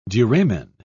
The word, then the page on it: duramen: British English